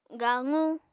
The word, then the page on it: gaqu: apatani